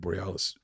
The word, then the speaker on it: Boreanaz: But David Boreanaz used to, am I saying his name wrong? – Boreanaz. – Boreanaz yeah.